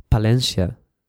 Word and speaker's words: Palencia: D-A-V-I-D. Okay. And my surname is Palencia. So it's P-A-L-E-N-C-I-A.